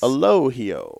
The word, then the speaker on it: Elogio: Out in the audience that wants to look up or try to track this cigar down, it's E-L-O-G-I-O. – Yes. The G is... – Elogio. – It's an H in the pronunciation.